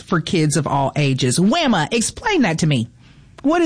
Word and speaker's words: WAMA: The WAMA, am I saying that right, Angela? W-A-M-A? – The WAMA.